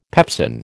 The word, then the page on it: pepsin: American English